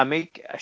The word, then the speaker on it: Amick: I liked I I remember liking I'm Dangerous Tonight just because it had mentioned I can never pronounce her name. – Amick. Amick. – Yeah, Amick. She was gorgeous in it.